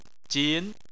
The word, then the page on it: jin2: chinese